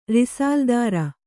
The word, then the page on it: ರಿಸಾಲ್ದಾರ: kannada